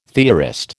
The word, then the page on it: theorist: American English